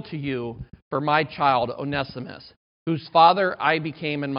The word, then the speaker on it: Onesimus: This is Paul's plea for Onesimus. I always used to call him Onesimus. It's Onesimus. O-N-E-S-I-M-U-S. Onesimus, okay?